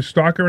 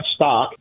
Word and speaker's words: stalk: Sure, it's the way it's spelled. There's an L in there. It's stalk. You know, it's not stock. Stock is stock, and stalk is stalk.